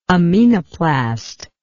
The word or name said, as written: aminoplast